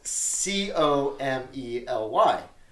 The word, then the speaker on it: comely: You see it, and it's it's spelled C O M E L Y. – Okay. Which is which is pronounced comely.